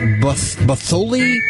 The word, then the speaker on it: Butthole: This is from Richard, I guess you pronounce it Buttholi, B-U-T-T-H-O-L-E. – Buttholi? – B-U-T-T-H-O-L-E. – Oh. – Wouldn't that be Buttholi? – Sure. – Buttholi. Yeah, that'd be Buttholi.